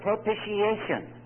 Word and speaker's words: propitiation: Some mispronounce it, but it is the word propitiation.